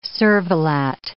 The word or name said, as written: cervelat